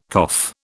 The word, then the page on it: cough: British English